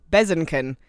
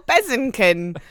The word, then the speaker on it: Bezançon: it tries to tell you is pronounced Bezenken, which is absolutely not correct. – Yeah, it's me, Bezenken. – It's Marcel Bezenken.